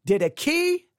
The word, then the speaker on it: Didache: The Didache, and I'll spell it D-I-D-A-C-H-E, D-I-D-A-C-H-E, and again, I've been taught Didache, and I've been taught Didache, all right? Didache maybe is the most correct.